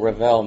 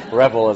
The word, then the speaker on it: Revell: As Harvey said, my name is Roger Revell. Revell makes it sound more auspicious than it actually is. Revell is how it's pronounced in the South. Maybe it was Revell way back when the Normans invaded England or something, back in the period of time which we're going to be looking at today.